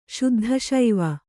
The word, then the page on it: ಶುದ್ಧಶೈವ: kannada